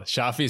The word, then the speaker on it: Shafi: Well, so first of all, am I saying your name correctly when I say Shafi? – Oh, perfect. It's like you gave me my name. Yeah, it was excellent. – Is that right? Are you making fun of me? – No, not at all. Shafi is perfect.